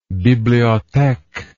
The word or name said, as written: bibliotheque